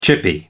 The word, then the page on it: chippy: American English